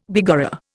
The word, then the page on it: begorra: Indian